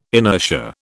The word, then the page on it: inertia: British English